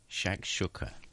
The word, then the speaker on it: shakshuka: It's spelled S-H-A-K-S-H-U-K-A. – Shakshuka.